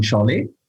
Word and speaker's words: Saleh: Saleh ist geschrieben, richtig ausgesprochen heißt es dann Schale, ist ein türkisches Geschlecht.